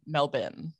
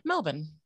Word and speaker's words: Melbourne: Really, really, because you want to say Melbourne, but it's definitely Melbourne. Like, it's just, you just got to shorten it, and that's your golden ticket, especially because like we don't really pronounce our R's as much. So you're not going to put that emphasis on the O-U-R. It's just Melbourne.